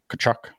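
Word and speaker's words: Tkachuk: That's 9 4, round 9, pick 4, followed by John Tavares and Steven Stamkos, then Matthew Tachuk, Tachuk, Kachuk, as Nick likes to tell me. The T is not pronounced.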